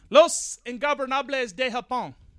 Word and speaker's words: Los Ingobernables de Japón: Tanahashi, the John Cena of New Japan, and Kushida, my boy, versus Bushi, Evil, Naito, and Takahashi, the also known as Los Ingobernables de Japón.